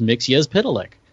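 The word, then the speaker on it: Mxyzptlk: And in that magazine one time, and I have no idea why this particular tidbit of information lodged in my brain, but they gave the phonetic pronunciation of that name, and it was Mixie Spitalik.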